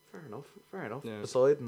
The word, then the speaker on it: Poseidon: Poseidon? – Poseidon.